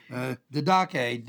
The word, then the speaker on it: didache: The word teaching is the Greek word didache, D-I-D-A. I can't read my own writing here. Didache, D-I-D-A-C-H-E, or didache is the way you would pronounce it with the accent there.